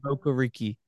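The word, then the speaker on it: Okereke: Yeah, is it Okur? I don't know how to say it. Okur? Yes. Okuriki? Okureke? – Yes, Okuriki. Yes, that's who it is.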